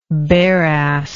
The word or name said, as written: bareass